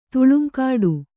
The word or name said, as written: ತುಳುಂಕಾಡು